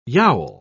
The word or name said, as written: yowl